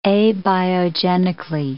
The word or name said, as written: abiogenically